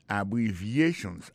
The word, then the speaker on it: abbreviations: Abbreviations, accent tonique la, tombé sous troisième syllabe la. Dans mot ça, il y a quatre syllabes. C'est A, une syllabe. Bri, c'est deuxième syllabe là. Vie, c'est troisième syllabe là.